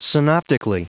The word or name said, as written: synoptically